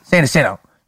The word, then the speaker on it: San Jacinto: Am I saying that right? – Jacinto? – San Jacinto.